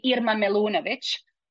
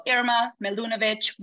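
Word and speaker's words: Irma Milunović: So the way I would actually pronounce it, my full name, as if I would do it in my language, which is Bosnian, would be Irma Milunovic. But I always allow people to go a little bit, you know, sideways with it. So Irma Milunovic, whatever, however you want to call me, I'll answer.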